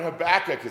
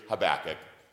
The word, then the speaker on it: Habakkuk: I asked Reverend Casperson in Sunday school today, how do you say it? He goes, Habakkuk. That's how I always learned it too. With potato, potato, Habakkuk or Habakkuk, you can do either one, just say the name.